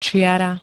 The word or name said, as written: čiara